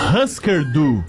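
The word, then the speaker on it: Hüsker Dü: Husker Du.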